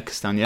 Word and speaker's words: Castagna: Castagna? – Yeah, Castagna.